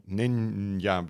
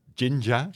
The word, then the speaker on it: Jinja: It's not ninja, but Jinja. – Jinja, yeah. – That's at least how I pronounce it.